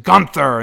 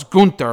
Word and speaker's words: Gunther: They did seemingly set up Breaker and Ziggler, and Breaker and, I was going to say Walter, Gunther, whatever the fuck it is. I love how she pronounced it Gunther, and then he was like, no, it's Gunther or whatever.